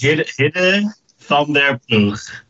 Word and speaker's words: Hidde van der Ploeg: It's Hidde van de Ploeg.